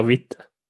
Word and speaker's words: Witte: Witt, antar att det uttalas, stavas Witte. Vi eh, säger Witte. Vi säger Witte.